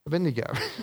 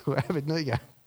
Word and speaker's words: Abednego: And so Daniel became Belteshazzar, Hananiah becomes Shadrach, Mishael becomes Meshach, and Azariah becomes, however you want to pronounce it, Abednego, Abednego.